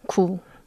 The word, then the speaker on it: 구: And the sound, pronunciation is 구.